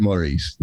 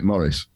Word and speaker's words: Maurice: Well, English people don't pronounce it Maurice. They just pronounce it Morris.